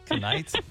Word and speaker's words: knight: Knight? – Up next. – Hey, she works at a school. She'll tell you the K is silent.